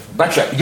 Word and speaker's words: Bathsheba: Bathsheba, yeah.